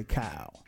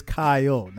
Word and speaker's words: Kyle: You're probably going to say Kyle. It's two syllables. It's Kyle, not one, Kyle.